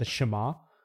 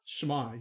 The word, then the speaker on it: Shema: Yes, this sounds a lot like I think it's the, you pronounce it the Shema. – Shema, yeah.